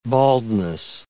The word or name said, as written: baldness